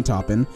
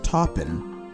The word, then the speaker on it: Taupin: One, Elton John did write all of the original songs for The Lion King with Tim Rice, not with Bernie Taupin. And two, it's pronounced Taupin, not Taupin.